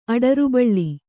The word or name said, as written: ಅಡರುಬಳ್ಳಿ